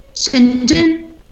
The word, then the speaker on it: Shenzhen: Bueno, ahí, a ver, a ver bien, ahí, ¿cómo se pronuncia? – Shenzhen. – Ahí está, bien, bien, ahí fonéticamente.